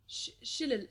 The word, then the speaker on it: shillelagh: What was the shindillalag? What was it called? – Shillelagh. – Shillelagh. Shillelagh. – Editor's note. We have Googled it and it's pronounced shillelagh. That's shillelagh.